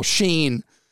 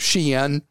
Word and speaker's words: Shein: And I've been asked an amazing number of times why we never talk about what Americans call Shein, but I think the proper way to pronounce this is Shein.